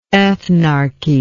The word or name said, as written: ethnarchy